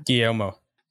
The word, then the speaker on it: Guillermo: My plan was to watch it immediately after watching the one, like Guillermo, how do I say that? – Guillermo.